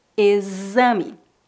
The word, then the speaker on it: exame: Observe que na palavra exame, o X é pronunciado como Z, exame.